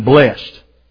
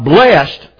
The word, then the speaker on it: blessed: It's been blessed. All right? In that case, you pronounce it as blessed, one syllable. But blessed, pronounced with two syllables as blessed, is an adjective that describes a noun or a pronoun.